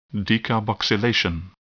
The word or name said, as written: decarboxylation